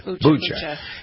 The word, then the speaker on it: Bucha: You pronounce it Bucha? Bucha? – How do you pronounce it? – Bucha.